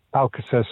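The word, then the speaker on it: Alcácer: Matt, there is a suggestion that the guy who's been sort of pushed out of the Dortmund side, is it Alcacer? Alcacer, sorry, my pronunciation awful. – You're right, mate.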